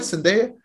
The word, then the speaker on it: Zendaya: So the fact that he got to save Zendaya, am I saying that right? Zendaya? – Yeah.